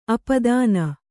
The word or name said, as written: ಅಪದಾನ